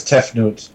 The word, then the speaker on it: Tefnut: We have the avatars for the Egyptian gods Hathor, Osiris, Horus, Isis, and Difnut? – I think it was Tefnut. – Tefnut, it begins with a D, but maybe it's pronounced with a T.